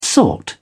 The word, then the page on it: sought: British English